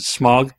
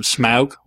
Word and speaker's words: Smaug: Yes, they're trying to defeat the dragon Smaug, which, by the way, I've always pronounced it Smaug in my head. But everyone in the movie calls him Smaug, which still sounds really, really weird to my ears.